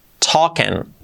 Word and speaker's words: talking: Por lo tanto, he is talking, contraemos, he's talking, así, con la S un poco seguimos así para ir al fino, he's talking, es un poco más la, no es ni O ni A, talking, talking. Y la I como una E y la G casi no se pronuncia.